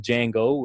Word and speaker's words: Django: And most recently, you know, my son's name is Django with the D is silent.